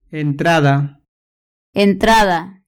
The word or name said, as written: entrada